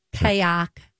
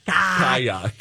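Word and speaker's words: ka'ak: I think it's pronounced kayak. Kayak. – Kayak? How's it spelled? – K-A. – Uh-huh. – Apostrophe A-K. Kayak.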